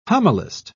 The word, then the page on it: homilist: British English